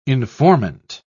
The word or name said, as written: informant